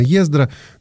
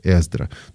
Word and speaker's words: Эздра: Тут спрашивают, а как правильно? Ну, в русской традиции, конечно, Ездра, но такого звука, как «е», изначально не было у евреев. В еврейском произношении, конечно, это не «е», а «э». И сегодня все больше и больше христиан, и не только христиан, читают это как «э», Эздра.